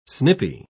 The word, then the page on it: snipy: British English